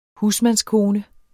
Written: husmandskone

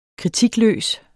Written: kritikløs

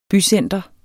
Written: bycenter

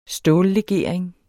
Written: stållegering

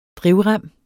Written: drivrem

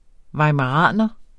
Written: weimaraner

